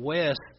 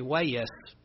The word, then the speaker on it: West: There's older people that still uses two syllables when they pronounce my name. Instead of West, they say Wayest. I don't know how you can get two syllables out of West, but they can.